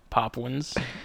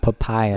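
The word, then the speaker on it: Papuan: It is Papwin, not Papayan.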